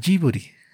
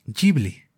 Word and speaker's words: Ghibli: En japonés, en lugar de que el estudio se se, llame Giburi, que sería lo más cercano a la palabra original, Miyazaki lo nombra Jiburi. Si ven videos de entrevistas en japonés a Miyazaki o a personas que trabajan en este estudio, pueden escuchar esta pronunciación, Jiburi.